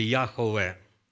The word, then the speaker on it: Яхве: На самом деле Яхве. Яхве. Короче, как мы говорим, укорочено Яхве. Ну, неважно. Пусть будет Иегова, пусть будет Яхве.